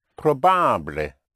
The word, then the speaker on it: probable: Because ibly in Spanish is pronounced ible, because the letter I in Spanish is always pronounced E. And ably in Spanish is pronounced able, because the letter A in Spanish is always pronounced A. So we have ible and able. So if you want to say possible would be posible, and probable would be probable.